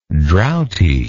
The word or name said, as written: droughty